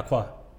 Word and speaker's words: Acquah: Yeah, Acquah. – Acquah. – Acquah, how do you spell that? – So A-C-Q-U-A-H.